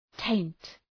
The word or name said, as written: taint